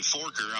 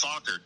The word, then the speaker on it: Fokker: And he goes, at first he was saying Forker. I was like, no, it's Fokker.